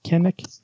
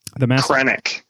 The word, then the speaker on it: Krennic: Kennick? – Krennic.